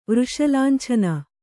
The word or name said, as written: ವೃಷಲಾಂಛನ